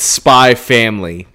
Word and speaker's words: Spy x Family: Are you supposed to say Spy X Family, Spy Cross Family, or Spy By Family? – It's Spy Family. The X is silent. This is a Hunter Hunter situation.